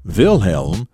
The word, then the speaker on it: Wilhelm: And of course, in Germany, Wilhelm, spelled W I L H E L M, is pronounced Wilhelm.